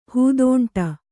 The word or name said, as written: ಹೂದೋಂಟ